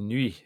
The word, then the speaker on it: nuit: nuit in French, that it was definitely night and not noodles.